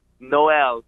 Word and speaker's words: Noel: Noel, just like Christmas.